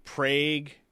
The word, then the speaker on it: Prague: Prague. I think that's how it's pronounced.